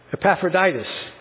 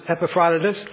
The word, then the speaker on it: Epaphroditus: Now, some of you have probably heard his name in the past, and you might have heard it pronounced, I got to think about this for a minute, Epaphroditus. It seems that the Greek New Testaments that we have now don't put the emphasis on those syllables. It seems that the way that the Greek New Testament wants his name to pronounce is Epaphroditus.